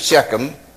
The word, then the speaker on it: Shechem: The village of Sychar in Samaria was near a place called Shechem. Some people may pronounce it Shechem.